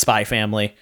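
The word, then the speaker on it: Spy × Family: Uh, it's just pronounced Spy Family it's one of those same things where it's like Hunter X Hunter, where it's just Hunter Hunter. This one's just Spy Family.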